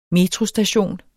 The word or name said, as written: metrostation